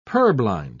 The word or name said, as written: purblind